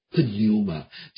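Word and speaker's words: Pneuma (πνεῦμα): 它叫 Pneuma，就是这个派啊叫坡啊派，这个也发音的啊，这个也发音的，在英文当中有一些这方面的词啊尤其是辅音它不发音的，但是呢在希腊文这种辅音也发音叫做 pneuma，它有一个短促的 pneuma 这样发音。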